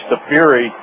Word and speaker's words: Safiri: the pronunciation of his last name, Safiri, as in Lake Erie.